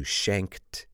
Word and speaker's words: shanked: Now, it doesn't end in a T. Of course, in the past, it's spelled S-H-A-N-K-E-D, but it sounds like a T. Shanked. And one last question here. How do you spell four? Nope, it's not F-O-U-R.